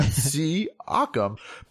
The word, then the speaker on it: Siakam: But the way Pascal's his name looks on paper Siakam. Is not the way it sounds, you know? – I'm gonna phonetically write it out next time. Siakam.